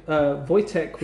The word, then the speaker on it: Wojtek: It's spelt W-O-J-T-E-K. – Sorry, W-O-J. – Can I read the word? – T-E-K, and it's spelt, and it's pronounced Wojtek.